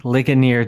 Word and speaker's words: Ligonier: It's pronounced Ligonier, dude. It's named after the city in Pennsylvania where RC hailed from.